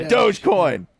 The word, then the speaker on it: Dogecoin: GameStop, AMC, Dogecoin. We're all going in on Dogecoin. – Is that how you pronounce? Oh, okay. – Dogecoin.